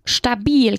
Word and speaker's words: stabil: Y estable se traduce con stabil, que es una palabra nueva aquí y se pronuncia con una A muy larga. Stabil.